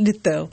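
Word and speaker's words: Литтелл: Во-первых, как правильно говорить? Потому что у нас был в программе Владимир Владимирович Познер. Он сказал, когда я сказала Джонатан Литтл, он мне поправил, что Джонатан Литтл. – Нет, Литтл, конечно.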